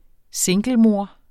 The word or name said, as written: singlemor